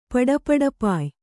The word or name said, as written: ಪಡಪಡಪಾಯ್